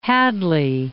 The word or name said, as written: Hadley